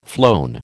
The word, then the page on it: flown: American English